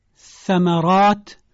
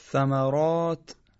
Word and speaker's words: ثمرات: ثا یعنی سه نقطه، سر زبانی تلفظ میشه، مثل ثمرات. تکرار بفرمایید. – ثمرات.